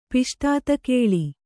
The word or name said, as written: ಪಿಷ್ಟಾತಕೇಳಿ